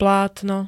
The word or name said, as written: plátno